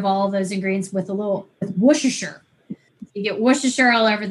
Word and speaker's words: Worcestershire: I think it's Worcestershire. I'm pretty sure that's the proper pronunciation.